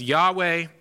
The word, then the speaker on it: Yahweh: We usually pronounce it in English as Yahweh.